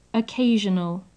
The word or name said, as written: occasional